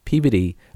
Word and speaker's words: Peabody: And I don't know that I've ever actually asked them that much about why they chose Peabody. Oh, in Massachusetts, we pronounce it Peabody. – Ah, Peabody. It sounds yeah, like puberty.